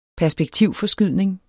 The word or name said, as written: perspektivforskydning